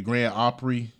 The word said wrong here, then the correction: Grand Ole Opry: Grand Ole Opry.